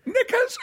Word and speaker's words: knickers: It's like, he said, when you say knickers, And I said, but the K is silent.